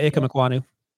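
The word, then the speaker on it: Ikem Ekwonu: Ikwanu. Did I do that right? – Yeah, Ikem Ikwanu.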